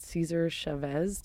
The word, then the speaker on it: Cesar Chavez: It's a panel of women, females, adult human females, and it's at the Austin Central Library on West Cesar Chavez.